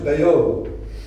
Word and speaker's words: Bayeux: It's pronounced Bayeux.